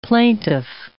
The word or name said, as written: plaintiff